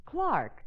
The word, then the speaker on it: clerk: Honey, in England, the word clerk is pronounced Clark.